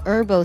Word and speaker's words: herbal: 所以呢，也可以叫做 herbal soup 或者 herbal soup。 Does the herbal soup？在英式的发音当中呢，会把这个“ 呵” 读出来，美式呢就去掉了。